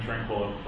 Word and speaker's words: Tranquillo: My name is Benjamin Tranquillo.